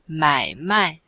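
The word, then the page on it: mai3mai4: tone 3; tone 4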